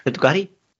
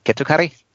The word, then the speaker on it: Kettukari: Kettukari. Kettukari, Kettukari, yes. Um, you need to be strict on those vocals.